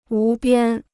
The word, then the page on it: 无边: wú biān